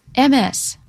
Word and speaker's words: Ms.: MS, Ms., capital M, small s, period.